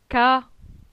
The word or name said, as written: Ca